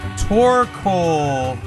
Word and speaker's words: Torkoal: This is the Torkoal.